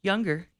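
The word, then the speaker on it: Junger: I'm not sure how to pronounce his last name, but I believe it's Sebastian Younger. – You pronounce it perfectly. Younger, yeah.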